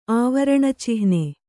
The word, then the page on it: ಆವರಣಚಿಹ್ನೆ: āvaraṇa cihne